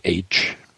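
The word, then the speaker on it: Aytch: So let's do that right now and find out about this classic Civil War memoir, Company H, A-Y-T-C-H, spelled phonetically for our benefit, Company H, or A Sideshow of the Big Show, by Sam Watkins, a high private of a Tennessee regiment.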